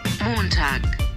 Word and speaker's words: Montag: Montag.